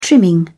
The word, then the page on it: trimming: British English